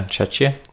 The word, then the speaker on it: Czechia: In Czechia, is that how you say it? – Yeah, Czechia.